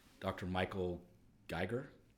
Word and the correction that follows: Greger: How Not to Die. – Oh yeah, yeah, yeah, Michael Greger. – Greger.